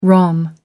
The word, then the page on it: rom: American English